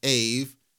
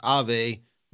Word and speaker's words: Aave: And yes, I pronounced it Aave. And I'm going to keep pronouncing it Aave. I'm not doing this Ave Maria nonsense.